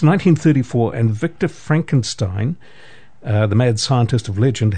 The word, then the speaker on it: Frankenstein: My name. It's pronounced Frankenstein.